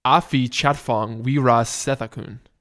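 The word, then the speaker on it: Apichatpong Weerasethakul: I'm pretty sure it's pronounced... I looked this up multiple times. It's pronounced Apichat Pong Wirasethakul.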